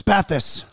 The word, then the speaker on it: Spathis: Joey Spatis or Spathis? – Spathis. – Joey Spathis is our producer. Usually it's phonetically pronounced.